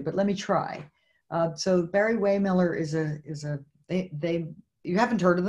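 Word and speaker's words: Barry-Wehmiller: Barry Waymiller, is that how you pronounce it? – That's exactly how you pronounce it.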